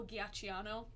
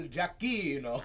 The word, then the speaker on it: Giacchino: I say Michael Giacchino, and I know that's wrong. – Michael Giacchino.